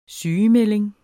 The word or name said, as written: sygemelding